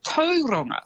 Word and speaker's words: Tauranga: And Tauranga as well is actually Tauranga.